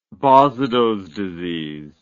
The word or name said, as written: basedows disease